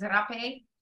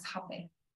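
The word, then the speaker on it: rapé: And then in Portuguese, they say it as rape. But I was getting flagged on certain search engines for the spelling of it, R-A-P-E.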